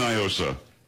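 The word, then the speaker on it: NIOSA: Do not, because it's definitely Nyosa. It is not a Spanish word. What does Nyosa mean in Spanish? Nothing. – I don't know. – Well, that's the whole argument. They try to pronounce it like it's Spanish. It's not.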